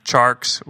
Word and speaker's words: Tjarks: We can follow you on Twitter at Jonathan Charks.